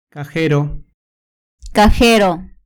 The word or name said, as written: cajero